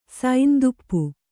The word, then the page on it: ಸಂತಾಪ: santāpa